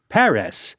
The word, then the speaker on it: Perez: I'll pronounce it like a good Spanish-speaking baseball fan, Tony Perez.